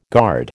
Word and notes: guard: American English

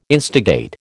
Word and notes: instigate: American English

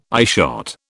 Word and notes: eyeshot: British English

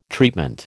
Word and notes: treatment: American English

